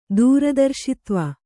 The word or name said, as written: ದೂರದರ್ಶಿತ್ವ